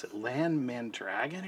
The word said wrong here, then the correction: Mandragoran: I'm saying his name wrong. Land something. No, Mandragoran.